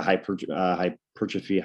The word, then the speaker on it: hypertrophy: And then I move more into hypertrophy, or how do you say the word? – Hypertrophy.